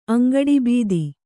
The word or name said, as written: ಅಂಗಡಿಬೀದಿ